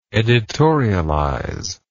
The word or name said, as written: editorializer